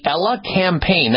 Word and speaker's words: elecampane: If you walked into a health food store and you asked for elecampane, elecampane, that's how you pronounce it.